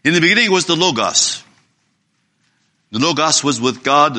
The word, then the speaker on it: logos: So I will give that O a long O sound like in the English language, and the other O would be like this pronunciation of the word hot. So you have logos. Yeah, you got that? You got the pronunciation there? It would be like a long O, the first O, and the second O would be more like a, as I said, logos.